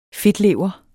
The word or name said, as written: fedtlever